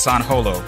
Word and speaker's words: San Holo: It's it's spelled it's it looks like it's spelled San uh, San Hollow, but it's pronounced San Holo, like the way you pronounce Han Solo.